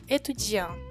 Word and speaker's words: étudiant: Você vai ver que étudiant e intelligent são escritos com T no final, mas a gente só pronuncia até o N.